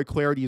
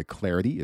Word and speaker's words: Clardy: At Troy Clarity is the way to go there. Last name is spelled C-L-A-R-D-Y. At Troy Clarity is the way to go to follow me on Twitter.